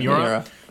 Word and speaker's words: Miura: How you pronounce it? – Yeah, Miura.